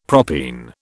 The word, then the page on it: propene: British English